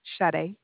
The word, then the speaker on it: Sade: Sade. It's spelled Sade? – S-A-D-E.